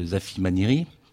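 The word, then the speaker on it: Zafimaniry: Zafi Maniri, on prononce comme ça, ont des thématiques qui peuvent ressembler beaucoup au mandala.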